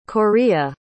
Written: Corea

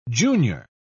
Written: junior

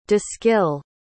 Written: de-skill

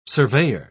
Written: surveyor

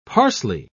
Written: parsley

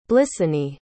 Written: blissany

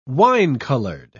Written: wine-colored

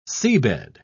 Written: seabed

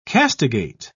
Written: castigate

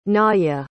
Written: naja

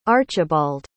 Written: archibald

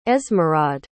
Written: esmeraude

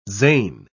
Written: Zain

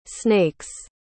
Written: snake's

